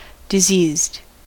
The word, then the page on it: diseased: American English